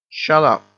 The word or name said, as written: shut up